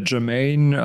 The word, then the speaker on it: Germane: Die Germane. Heißt das so? Wird es ausgesprochen? – Genau, ne? Germane, also die jetzt eigentlich, ne?